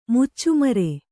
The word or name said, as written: ಮುಚ್ಚುಮರೆ